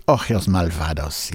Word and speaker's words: Ojos Malvados: Het nummer Ojos Malvados. Zeg ik dat goed? Ojos Malvados, ja.